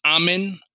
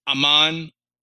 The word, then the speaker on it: Amun: It should also be noted that the title Most Secret One coincides with the ancient Kemetic word pronounced Amen, Aman, or Amun, which translates as Hidden One.